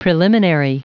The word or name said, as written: preliminary